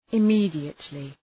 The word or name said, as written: immediately